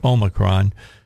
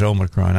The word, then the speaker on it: Omicron: Did you watch the president yesterday and see what he had to say about COVID and about Omicron? The proper pronunciation, by the way, is Omicron. I don't know where the Omicron came from, but that's what they started calling it.